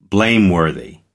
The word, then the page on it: blameworthy: American English